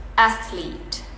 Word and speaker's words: athlete: five four three two one the correct one is athlete cuman two syllables ya